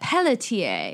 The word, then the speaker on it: Pelletier: We're talking Paul Pelletier. – I would pronounce that Pelletier. – Pelletier.